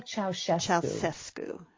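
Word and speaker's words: Ceaușescu: Can you pronounce Yes, the leader, the dictator in Romania was Nicolae Ceausescu. – Ceausescu. And his wife was right there with him, wasn't she? – Oh, it was as if Romania was ruled by two leaders. Elena Ceausescu, his wife, also had power over the people.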